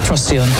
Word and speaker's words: Procyon: The brightest star in the constellation, Canis Minor, which I stumbled on, obviously, as when I was checking that it was Procyon rather than any other pronunciation that I was attempting earlier.